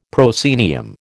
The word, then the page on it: proscenium: American English